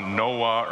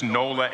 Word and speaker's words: Nola: By the way, my wife's name is Nola, N-O-L-A, and when I say it, I don't know what happens, but it comes out Noah or Nora. Nonetheless, we've survived 4.5+ decades in marriage despite that. So don't feel bad if you don't get her name right, but it is Nola, N-O-L-A, right?